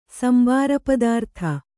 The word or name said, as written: ಸಂಬಾರಪದಾರ್ಥ